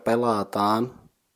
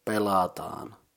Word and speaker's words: pelataan: The second word pelataan is consisting of three syllables, pe, la, and taan. The first syllable pe is the same as in pension, so pe. The second syllable la is pronounced like in the game's name La Granja, just the beginning la, and that's it.